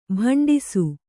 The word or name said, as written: ಭಂಡಿಸು